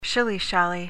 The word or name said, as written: shilly-shally